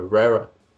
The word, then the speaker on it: Vera: como diriam os americanos, não, é Vera, é Vera.